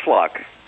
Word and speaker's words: Fluke: My name is Ferris Fluck. It's spelled F-L-U-K-E.